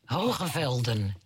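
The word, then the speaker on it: Hoge Veluwe: There's a park to the north, which I won't pronounce properly, Hogevelva. – Hogevelden, yes. – But, yeah, your pronunciation's way better than mine.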